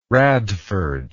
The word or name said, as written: radford